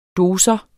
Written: dosis

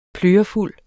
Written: plørefuld

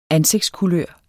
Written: ansigtskulør

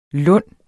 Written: lunde

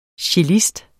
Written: CELLISTEN

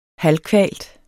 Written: halvkvalt

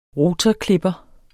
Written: ROTORKLIPPER

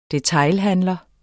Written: detailhandler

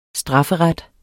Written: strafferet

